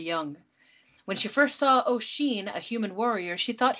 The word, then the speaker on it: Oisín: This is a story of Niamh and Oisín. Not pronounced, not written to be pronounced like that at all. I had to Google that.